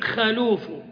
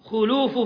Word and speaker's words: خلوف: خلوف فم الصائم ينطقها بعض الناس بفتح الخاء في قولهم خلوف، وأكثر أهل العلم على تخطئة ذلك وأنه لا يصح، وإن ذهب بعض أهل اللغة إلى الجواز، جواز الفتح والضم، إلا أن أكثر أهل العلم، بل إن الإمام النووي رحمه الله شدد في هذه المسألة وأنها تنطق بالضم، فيقال خلوف فم الصائم.